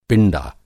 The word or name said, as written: पिंडा